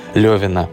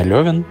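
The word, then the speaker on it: Лёвин: вас больше зацепит история Левина. Кстати, правильно говорить именно Левин, а не Левин, хотя я изначально этого не знал, но оказывается так, потому что Лева, Лев Николаевич Толстой, прототип этого героя.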